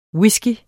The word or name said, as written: whisky